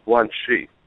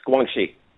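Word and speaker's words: Guangxi (关系): So you pronounce it as Guangxi? – Yes, Guangxi. – Okay, so Xi is pronounced with SH almost. – It is, yes, it is.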